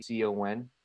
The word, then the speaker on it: Yacon: Yeah. Yukon root. But people can just say Yukon root in North America, you know, or whatever they want to say. It's Yacon. From Peru.